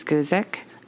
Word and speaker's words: Guzik: It's Linda Louise Guzik. – Okay. – I apologize if I'm saying her last name wrong. It's spelled G-U-Z-I-K. – I mean, that's how I would say it.